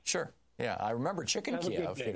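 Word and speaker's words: Kyiv: please, don't say Kiev, say Kiev. – Kiev is the Russian pronunciation. Kiev is the Ukrainian pronunciation.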